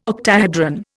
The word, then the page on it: octahedron: Indian